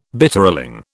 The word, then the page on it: Bitterling: British English